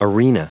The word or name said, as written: arena